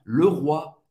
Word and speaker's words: Leroy: Para fazer esse E, você vai ter que fazer a boca do O e falar E. E, E. Le roi. Le roi. comme hein, como rei. Le roi. O Y français, ou ou O I tem a pronunciar do wa. Wa.